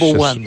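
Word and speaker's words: Ayubowan: Dzień dobry, Ayubuan. – Ayubuan, o tego nie wiedziałem. Nauczyłeś Ayubuan. – Się... – Bardziej to jest takim akcentem na koniec.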